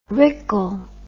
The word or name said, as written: rickle